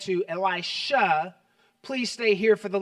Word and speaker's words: Elisha: By the way, any scholars in the room, I know it's pronounced really Elisha, but I grew up with Elisha, and old habits die hard.